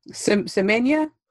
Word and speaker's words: Semenya: So somebody else who should be getting some points, but it looks like she will not, is Casta Semenya. Did I say that right, Bartender? – Semenya? – There we go.